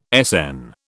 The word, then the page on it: Sn: British English